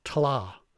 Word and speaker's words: Tlath: it's Tla, which is spelled T-L-A-T-H.